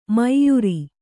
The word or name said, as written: ಮೈಯುರಿ